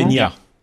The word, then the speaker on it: Peña: et, le N avec une virgule dessus, ça se dit comment Peña. – Peña. Javier Pena.